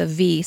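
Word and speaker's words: Av (אב): As you all know, the Hebrew word for father is Av, or it's A-B, but it's pronounced with a V, so Av.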